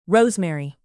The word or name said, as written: rosemary